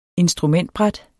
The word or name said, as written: instrumentbræt